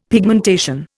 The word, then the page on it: pigmentation: Indian